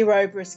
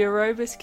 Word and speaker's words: Ouroboros: It's the Ouroboros Key.